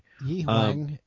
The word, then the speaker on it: Yihuang: In two counties or provinces, which I cannot pronounce, Yunhong and Yaxi province, is encouraging. I know I pronounced those wrong. I'm sorry. Don't come for me. – Yihuang and Jiangxi.